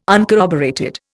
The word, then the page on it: uncorroborated: Indian